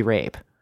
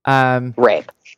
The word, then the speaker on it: Rabe: How do you pronounce her? – Because Lily Broccoli Rob. I actually, interviewed her, years and years ago when I was working at Yahoo, and I think it's Lily Rabe.